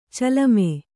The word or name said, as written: ಚಲುಮೆ